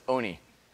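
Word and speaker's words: Olney: Okay, this is Oni. The L is silent. So it's Oni.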